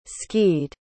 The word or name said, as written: skied